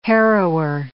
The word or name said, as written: harrower